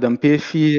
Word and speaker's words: Ampefi: Sì, io mi trovo, come detto bene, ad Ampefi, un po' meno di 300 chilometri, sono circa, diciamo, 3 ore di macchina, ecco, dalla capitale.